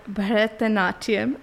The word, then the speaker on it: Bharatanatyam: I do an art form called Bharatanatyam.